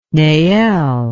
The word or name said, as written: neel